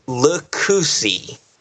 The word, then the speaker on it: Lucchese: It is pronounced Lucchese. That makes sense. That's like a failure to my Italian brethren, yeah. But uh, L U C C H E S E is how you spell it.